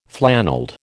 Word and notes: flannelled: American English

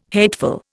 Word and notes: hateful: Indian

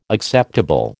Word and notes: acceptable: American English

